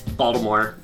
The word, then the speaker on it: Baltimore: We don't pronounce Baltimore with a T, it's Baltimore.